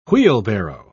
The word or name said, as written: wheelbarrow